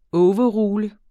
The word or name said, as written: overrule